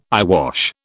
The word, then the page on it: eyewash: British English